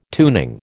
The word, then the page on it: tuning: American English